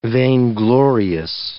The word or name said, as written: vainglorious